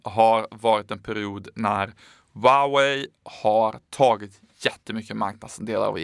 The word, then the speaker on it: Huawei: de som vissa uttalar Huawei här i Sverige, men så ska det alltså inte sägas utan det heter Huawei.